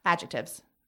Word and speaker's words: adjectives: Well, okay, it's adjectives, but there is a C in there. It's it's spelled adjective, so is the C silent? – It's adjectives. Unfusing. The C is silent and confusing.